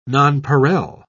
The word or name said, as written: nonpareil